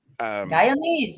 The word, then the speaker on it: Guyanese: the poor Guyanese, is that how you say it? Guyanese? Guyanans? – Guyanese, you're right.